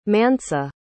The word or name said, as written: mansa